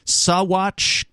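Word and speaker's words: Saguache: Sawatch County, Colorado. It's a strange word. It's spelled S-A-G-U-A-C-H-E. I guess you don't pronounce the G in that.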